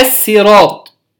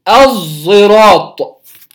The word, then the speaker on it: الصراط: إما أن تكون بالسين الصراط كما قرأناها في المد، الصراط وصراط بالسين، أو تقرأ بالصاد اهدنا الصراط، أو تقرأ بالصاد المشمة زايا، مشمة حرف الزاي، يبقى الصراط، الصراط بالصاد، الصراط، الصراط، تشبه الظاء المصرية. احنا لما بنيجي ننطقها ظاء في المصري في العامي بننطقها ظاء ظاء، لا نخرج اللسان، نقول ظاء ظاء، هي تشبه هذه مع الاستعلاء وإطباق الصاد، فيرتفع اللسان بالكلية إلى أعلى ويشم الزاي، وسيأتي تفصيل ذلك.